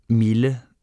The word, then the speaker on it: mille: There are three exceptions where i deux l is pronounced il and not y: mille, ville, tranquille.